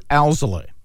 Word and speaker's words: Alzolay: Am I saying it right? Alave? – Adbert Alzolay. – Alzolay. There's an L in the front there.